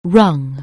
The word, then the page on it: rung: anglais